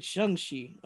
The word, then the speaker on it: Shang-Chi: Is it actually Shang? – Yes. – Okay, because I've been saying it incorrectly. Shang-Chi, okay.